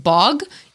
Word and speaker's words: bwg: It comes from boggard with a D, or the Welsh, I'm pretty sure it's pronounced bog, even though it's spelled B-W-G.